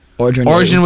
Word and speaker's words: Orgeron: Or Orgeron, is that how you say it? – Orgeron was apparently outraged, immediately quit.